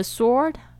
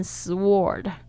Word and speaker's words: sword: According to rank, the sword。大家特别注意一下 sword 的这个声音呢，你不要念成 sword，因为它那个 w 其实是没有发音的哦，这边特别注意一下。